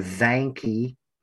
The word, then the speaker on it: Zahnke: I'd like to clarify, my name is pronounced Tyler Zanke.